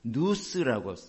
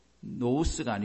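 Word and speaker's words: 누스 (νοῦς): 그런데 헬라어에서는요, 저걸 누스라고 써요. 누스. N-O-U-S인데 그 발음 나는 식이에요 저거. 노우스가 아니고 누스입니다.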